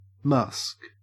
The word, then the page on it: musk: Australian English